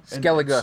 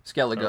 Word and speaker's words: Skellige: You know, Skellige. So, come on, sorry, Skellige. Man. I'm gonna have to pronounce some of these things right for you before some of our fans go. Skellige.